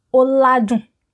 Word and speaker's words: oladun: Yes, it is odun. Odun. Er, oladun. Oladun. well done if you remember that.